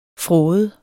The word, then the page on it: fråde: [ ˈfʁɔːðə ]